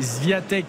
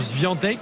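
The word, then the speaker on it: Świątek: On dit Crirovia Mais, mais, c'est Zviatek et ça se prononce Zviantek. Et donc Piatek, ça se prononce aussi Piantek.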